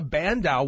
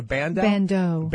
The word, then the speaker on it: bandeau: So I think a bandeau would be a better, is that how you say it, a bandeau? – Bandeau. B-A-N-D-E-A-U. – Like banjo with the D.